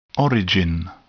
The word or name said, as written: origin